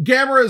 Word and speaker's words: Gamera: Gamera is the way it's traditionally pronounced, but at least in America. Whether that's the actual pronunciation in Japan, I can't say in this instance.